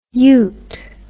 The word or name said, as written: yoot